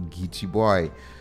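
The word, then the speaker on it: Geechie Boy: Well, you know, it is actually spelled. I'm pronouncing it exactly how it's spelled. G-E-E-C-H-I-E boy. So Gitchy boy. But see, that's you heard that right, Gitchy boy.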